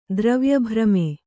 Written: ದ್ರವ್ಯಭ್ರಮೆ

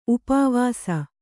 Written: ಉಪವಾಸ

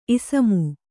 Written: ಇಸಮು

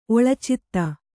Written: ಒಳಚಿತ್ತ

